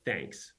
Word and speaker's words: Thnks: for work, I am the co-founder and CEO of Thnks, which is uh, T H N K S, but pronounced like you know, thank you.